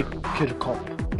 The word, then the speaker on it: Chircop: Yeah, Chircop is fine. In Maltese we say Chircop, but don't have to say Chircop, no problem.